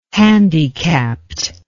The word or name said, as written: handicapped